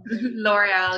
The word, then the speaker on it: L'Oreal: So I know L'Oreal is one of, is that how you pronounce it? I don't even know. – L'Oreal, yeah.